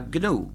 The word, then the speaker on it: GNU: Se escribe GNU. En inglés se pronuncia GNU, pero en castellano se pronuncia GNU.